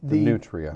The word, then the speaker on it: nutria: How do I pronounce this? – The nutria.